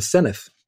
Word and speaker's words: Senedd: Mark Drakeford triumphed in Wales, just one seat of Labour's first ever majority in the Senedd, as I now know it is pronounced, while former Plaid leader, again, a learning curve, Leanne Wood, lost her seat.